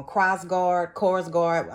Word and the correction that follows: Krogstad: I'm sorry. – Krogstad. Krogstad.